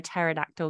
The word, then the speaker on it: pterodactyl: Why can't you hear a pterodactyl going to the toilet? – Because the P is silent.